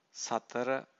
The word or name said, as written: satara